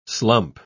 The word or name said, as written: slump